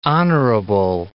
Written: honorable

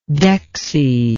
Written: dexie

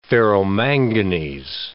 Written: ferromanganese

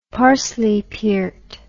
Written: parsleypiert

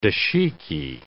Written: dashiki

